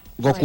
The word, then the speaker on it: Goku: Pero claro, que no es Goku, porque en Perú es Goku. Lleva acento en la U. Goku.